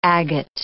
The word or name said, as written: agate